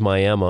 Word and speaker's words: Miami: And it is Miami, Oklahoma, even though it's spelled with an I at the end.